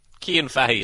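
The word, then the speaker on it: Cian Fahey: Kian Fahey is my name.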